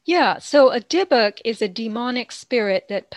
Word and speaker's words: dybbuk: Is it debuk? – Dybbuk. – Dybbuk, D-Y-B-B-U-K, in Jewish lore is a soul without a body.